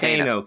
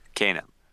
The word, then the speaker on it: Kano: How do you pronounce it? – Kano. – Yeah, Kano.